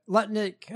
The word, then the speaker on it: Lutnick: Lutnick, oh, I said it right.